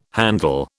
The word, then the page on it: handle: British English